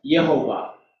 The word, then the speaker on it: Yehovah: And that gets translated into Latin, into our English language today as Yehovah, Jehovah. We call it Jehovah, but the actual way they, the Jewish people pronounce it, which they actually don't, is Yehovah, Yehovah, Yehovah, Yehovah, right? But we say Jehovah, right?